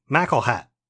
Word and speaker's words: McElhattan: It's MCELHATTAN. So it looks like McElhattan. They pronounce it McElhat.